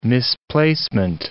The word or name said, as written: misplacement